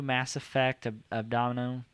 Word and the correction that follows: Andromeda: is that how you say it? – Andromeda, I'm butchering it.